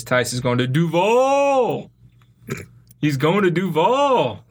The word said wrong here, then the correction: Duval: You're putting the emphasis on the wrong syllable. It's Duval.